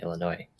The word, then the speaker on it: Illinois: And so, but in French, you apparently don't pronounce the S at the end of words, which is why you don't pronounce the S at the end of Arkansas and Illinois.